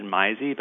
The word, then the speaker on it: Meise: It was spelled M-E-I-S-E. My family pronounced it Mize, but everyone always pronounced it different ways.